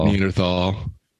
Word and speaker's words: Neanderthal: Neanderthal. – The H is silent.